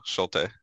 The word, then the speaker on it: Schulte: No, Schulte. – Okay, I think I said it wrong a couple weeks ago, but yeah, you said you flipped the L and the U.